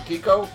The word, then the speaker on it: Kiko: Where I think the other guitar players, like, you know, your Chris Poland, your Chris Broderick, Kiko, is that how you pronounce it, Kiko? – Yeah, got it right.